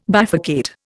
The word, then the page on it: bifurcate: Indian